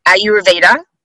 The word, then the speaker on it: Ayurveda: I'm going to say this wrong as I always do. Ayurveda. – Yay, nailed it.